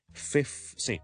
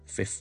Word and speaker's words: fifth: 因为 FIF 完之后先至 TH, 而这个 F 和 TH 呢两个音呢都不可以省略的，不像我们某些音可以拦着去，这个是不可以省略，两个音都要读。怎么读呢？ fifth 先不要搞那么多东西，先读了 FIF, fifth, fifth, fifth, 这个完全没问题啦。